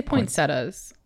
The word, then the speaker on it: poinsettias: I say poinsettias. I don't know why I don't pronounce that I.